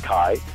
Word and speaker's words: chi: it's called chi, and the R sounding letter is called rho, and it's written as a big P. So you see the chi rho, these X and P on all the early Roman uh, fourth century art.